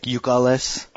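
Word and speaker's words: Youkilis: So Ucalis, they say it like Ucalis.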